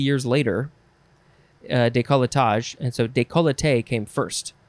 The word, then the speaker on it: décolleté: It is décolleté. Décolleté, décolleté, décolleté, décolleté. Yep. Or you can also, again, take off that extra syllable and say décolleté.